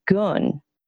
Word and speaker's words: Gen: So come back out, come to Khan again, and now we're going to go to our left, and that area is called Gun. It's spelled G-E-N. None of these Chinese names have any phonetic pronunciation.